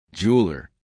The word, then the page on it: jeweler: American English